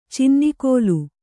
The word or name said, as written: ಚಿನ್ನಿಕೋಲು